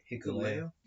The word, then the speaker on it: Hikuleo: Hikuleo? – Yeah. Ah, I was correct.